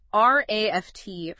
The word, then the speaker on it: RAFT: So, just to quickly set the stage, the RAFT framework, and that's pronounced just like the word raft, is a journey.